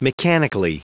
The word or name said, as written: mechanically